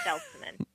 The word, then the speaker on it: Seltman: A lot of people insert a Z in there and make me Jewish. Seltman.